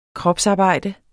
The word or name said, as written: kropsarbejder